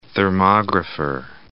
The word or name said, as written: thermographer